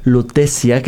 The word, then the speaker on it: Lutetia: donde levanto proyectos independientes, y hace unos meses terminé de filmar una película que se llama Lutecia, que se escribe Lutetia porque está en latín, y filmamos en París y en México.